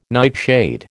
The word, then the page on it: nightshade: American English